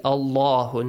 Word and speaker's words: Allahu: Wa idh akhad Allahu, the letter lam in the word Allah, since it has a fatha before, it should be pronounced heavily. – Allahu, not Allahum.